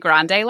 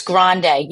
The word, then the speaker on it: Grande: Is it I've always heard grand lash. Is it technically grande lash? – It's Grande, yeah.